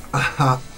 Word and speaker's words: ajá: ¿Por qué lo pronuncias con H? – Ajá. – Si esto es muy mendocino, si H, es, ajá. Ajá, es muda.